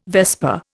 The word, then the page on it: vesper: Indian